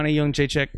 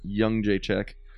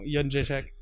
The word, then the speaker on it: Jędrzejczyk: Joanna Jędrzejczyk. – Young Jędrzejczyk. – No, Jędrzejczyk.